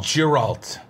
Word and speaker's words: Geralt: Luis 15 says, hard G. So is it Geralt? – Yeah. – Geralt. I'm calling him Geralt. – Geralt. – That's what I'm calling him from now on. – Encouragement. – Git Bucket says, no, that's not how it's pronounced, LOL.